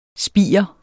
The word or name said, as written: spiger